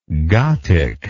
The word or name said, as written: gathic